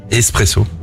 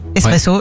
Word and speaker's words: espresso: Espresso. – Espresso, ouais. Étymologiquement, on ne prononce pas le X. L'origine du mot vient de l'italien esprimere.